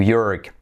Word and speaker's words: York: Number 34, el estado de Nueva York. New York. New York. New York. Letra semiclave Y, voy a decir, no decir chork, sino yo, y luego letra clave R, York, como for, more, York, New York.